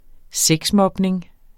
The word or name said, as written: sexmobning